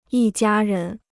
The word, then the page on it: 一家人: yī jiā rén